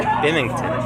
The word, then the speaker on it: Binghamton: Binghamton.